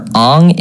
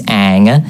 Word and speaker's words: Aang: You went for Aang, it's Aang. It's a double A.